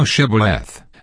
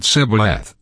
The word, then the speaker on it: Shibboleth: Then said they unto him, Say now Shibboleth, and he said Sibboleth, for he could not pronounce it right.